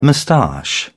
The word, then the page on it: moustache: British English